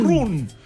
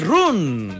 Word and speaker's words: run: Run. Run. – Sí, pero no se dice run. Se dice ¿cómo se dice? – No, run, no, run. Incluso casi más con A.